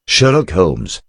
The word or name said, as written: Sherlock Holmes